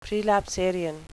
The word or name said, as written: prelapsarian